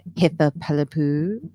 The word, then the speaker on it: Hitha Palepu: I'm Hitha Pelipu.